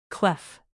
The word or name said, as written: clef